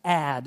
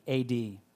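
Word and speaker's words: ad (עד): The word everlasting is a masculine noun, and it's pronounced ad, and it's spelled the same way that you would think, A-D.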